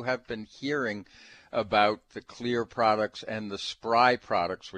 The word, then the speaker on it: Xlear: Well, our special guest today is the CEO of Xlear. That's spelled with an X, so it's X L E A R.